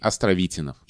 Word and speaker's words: Островитянов: Все дело в том, что человек, именем которого назвали улицу, ударение в его фамилии падает на «и» Островитинов.